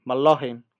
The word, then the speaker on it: McLaughlin: Jordan McLaughlin, ¿Lo he dicho bien? Sí, McLaughlin.